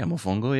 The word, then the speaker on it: mofongo: Am I pronouncing that right? – Yeah, mofongo, yeah.